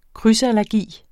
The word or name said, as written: krydsallergi